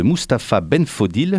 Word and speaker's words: Mustapha Benfodil: On va parler maintenant de Clandestinopolis, c'est une pièce de Mustapha Benfodil, est-ce que je l'ai bien prononcé ? – Très bien.